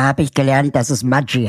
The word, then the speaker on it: Maggi: aber mit norditalienischen Eltern und eigentlich Maggi ausgesprochen wird.